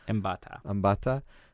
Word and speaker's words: Mbatha: I mean, both of the lead actors, you know, Mackenzie Davis and how do you pronounce it again? Gugu Mbatha-Raw? – Mbatha.